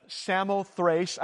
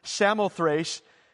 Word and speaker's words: Samothrace: I knew I'd get that. Samothrace. I looked it up. That's how you pronounce it. Samothrace.